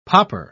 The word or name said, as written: popper